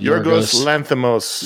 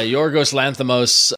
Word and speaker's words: Yorgos Lanthimos: Yorgos Lanthimos. – Yeah, Yorgos Lanthimos, you know, of you know, previously done The Lobster and...